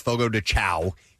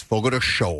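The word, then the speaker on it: Fogo de Chão: By the way, it's not pronounced Fogo de Chow. Even though it's spelled that way, it's Fogo de Show.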